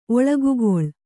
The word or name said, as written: ಒಳಗುಗೊಳ್